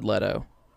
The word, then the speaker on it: Leto: But Jared Leto, or Leto, how do you say it? – I've always said Leto.